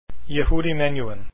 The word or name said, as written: Menuhin